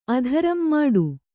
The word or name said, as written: ಮುಟ್ಟ